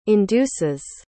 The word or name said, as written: induces